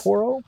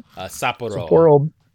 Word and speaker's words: Sapporo: Sapporo? – Sapporo.